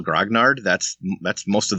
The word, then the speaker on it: Grognard: I said it pronounced it just like it's spelled, you know, Grognard, Grognard. It doesn't get any more simple than that.